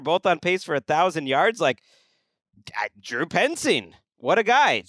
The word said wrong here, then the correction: Petzing: That's P-E-T-Z-I-N-G, Petzing, I would say.